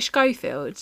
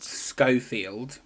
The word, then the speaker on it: Schofield: Where Philip Schofield. – Yeah. – Is that how you say it? Schofield? – Schofield. – Schofield. Yeah. Okay. Philip Schofield. Yeah, that sounds right.